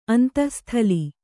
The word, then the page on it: ಅಂತಃಸ್ಥಲಿ: Kannada